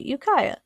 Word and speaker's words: Ukiah: Ukiah. Yukiha. Sounds like you. Oh, wait. Yuki, I definitely typed that in wrong. Ukiah. Okay, so I was right. Ukiah.